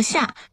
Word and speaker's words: 厦: 刚才于老师说到的这个抱厦呀，它那个抱厦的厦字其实就是厦门的厦，它是一个多音字。